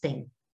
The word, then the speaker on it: têm: Na pronúncia não tem diferença, gente. Vamos falar muito claro, não tem. Ele tem, eles têm. Mas na hora de escrever, o plural tem acento, acento circunflexo. E para os verbos derivados do verbo ter,